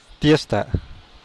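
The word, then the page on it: tiesta: Teotitlán del Valle Zapotec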